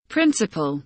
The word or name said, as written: principal